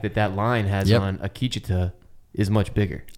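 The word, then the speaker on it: Akecheta: Did I say it wrong again? – Akicheta. – Fuck. Akicheta.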